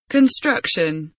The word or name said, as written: construction